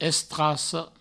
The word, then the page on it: estraca: Provençal; estrasso